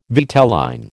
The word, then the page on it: vitelline: American English